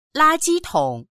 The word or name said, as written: la1ji1tong3